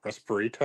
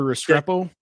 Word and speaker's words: Restrepo: I'm going to pronounce this guy's name more like Respirito or Respiro. – Oh, Xavier Restrepo.